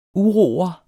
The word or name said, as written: uro'er